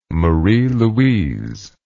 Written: marie louise

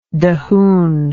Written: dahoon

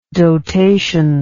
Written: dotation

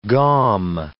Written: gaum

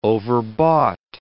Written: overbought

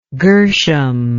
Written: gershom

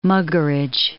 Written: Muggeridge

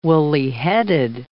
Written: woolly-headed

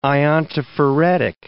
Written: iontophoretic

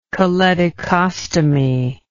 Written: choledochostomy

